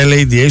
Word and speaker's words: Lada: Το Λάντα λέει προφέρεται καλύτερα LADA, σωστό.